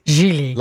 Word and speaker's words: Gigli: My producer's saying it's pronounced Gili. – Gili. – Like Gile.